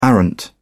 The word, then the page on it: arrant: British English